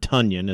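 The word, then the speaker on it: Tonyan: Generally speaking, it has been Tunyon, Robert Tunyon, as we've been told it is now pronounced, or has always been pronounced, I suppose.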